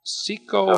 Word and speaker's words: caecal: Cecal? – That's pronounced. C-A-E-C-A-L. – Cecal feces. – Yes.